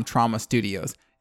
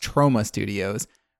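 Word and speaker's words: Troma Studios: And I had been saying that they were from Trauma Studios. It has been pointed out to me that that is actually pronounced Trauma Studios, which is crazy because I'd only ever read it, but that is 100% the correct pronunciation.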